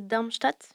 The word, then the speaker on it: Darmstadt: Elle a dû s'évader suite à l'attaque qu'elle a fait contre la prison de Darmstadt.